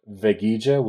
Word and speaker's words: Veguilla: I personally am on Twitter at J-I V as in Victor, E-G U-I-L-L-A. Um, I'm also on Facebook at Jorge Veguilla, which is spelled J-O-R-G-E. My last name is V as in Victor, E-G-U-I-L-L-A.